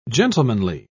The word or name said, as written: gentlemanly